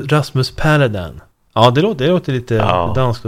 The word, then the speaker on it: Paludan: Jag sa ju tidigare Rasmus Paludan. Fick jag höra sen att jag var riktigt dum i huvudet som sa så. För han heter ju Paludan. Rasmus Paludan. Men Kasselstrand ger en ledtråd. För han uttalar namnet på ett annat sätt. Han säger Rasmus Paludan. – Ja, det låter ju danskare faktiskt. – Exakt, det är lite dansk, det är lite danskt här. Så istället för att det är liksom A-U-A-vokalerna så är istället Ä-Ä-Ä.